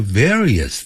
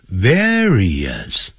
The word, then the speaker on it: various: They fight over various things. Various，他就一定要念成 various，不要念成 various。